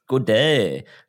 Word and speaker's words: Gaudet: G-A-U-D-E-T. Gauday? It's like, yeah. French Canadian, I guess. – Gauday. – Gauday.